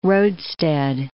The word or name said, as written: roadstead